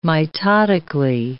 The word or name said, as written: mitotically